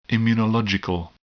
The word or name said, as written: immunological